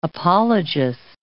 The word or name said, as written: apologist